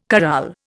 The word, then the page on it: corral: Indian